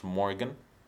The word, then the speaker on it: Morgxn: Or speaking of Morgan, have you heard the song Home by an artist called Morgan, except he spells it with an X instead of the A in the Morgan? So it's Morgxn, but it's still pronounced Morgan.